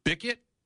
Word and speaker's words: Bickett: And of course, Xavier Bickett, rhymes with Wicket, the Ewok from Return of the Jedi.